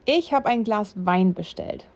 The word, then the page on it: Wein: German